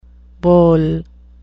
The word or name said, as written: bole